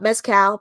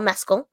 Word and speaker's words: Mescal: And that is how you pronounce his name, not Mescal, Paul Mescal.